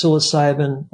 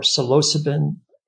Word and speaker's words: psilocybin: Such tools as psilocybin or psilocybin in the New Wave pronunciation, ayahuasca, mescaline, sophora, paganum, etc., all can be classified as entheogens.